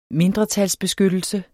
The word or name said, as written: mindretalsbeskyttelse